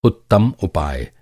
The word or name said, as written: उपाय